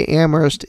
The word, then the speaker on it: Amherst: Those of you who don't know, the proper way to say Amherst is Amherst, not Amherst. – Yeah. Well, because there's other there's Amhersts out there. But this one, because we're hoity-toity, we say Amherst without the H.